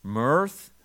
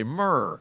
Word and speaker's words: mirth: And every time I see mirth, I want to say myrrh.